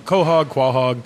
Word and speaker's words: quahog: Is that how it's pronounced? I've heard it quahog. – Quahog. My family always pronounced it quahog.